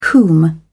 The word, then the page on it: whom: American English